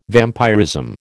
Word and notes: vampirism: American English

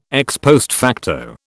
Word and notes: ex post facto: British English

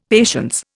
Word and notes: patience: Indian

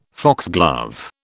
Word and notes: foxglove: British English

